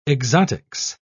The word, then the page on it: exotics: British English